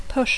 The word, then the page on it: push: English